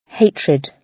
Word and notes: hatred: British English

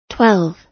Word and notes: twelve: American English

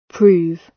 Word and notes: prove: British English

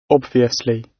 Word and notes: obviously: British English

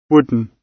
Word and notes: wooden: British English

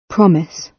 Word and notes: promise: British English

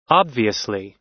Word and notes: obviously: American English

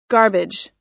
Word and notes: garbage: American English

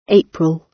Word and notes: april: British English

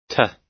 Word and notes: to: American English

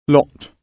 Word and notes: lot: British English

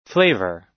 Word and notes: flavour: American English

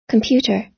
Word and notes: computer: American English

